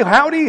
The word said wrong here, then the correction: haughty: howdy doody. You know, I mean, like, come on. But anyway, now I know it's pronounced haughty.